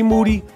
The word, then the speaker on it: Moody: G Moody, last name rhymes with duty.